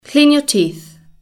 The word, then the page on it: clean your teeth: British English